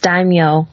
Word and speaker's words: daimyo: We have three levels the Ninja level, Sensei level, and the Daimo level. Is that how you pronounce it? No, it's daimyo.